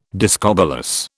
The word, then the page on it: discobolus: British English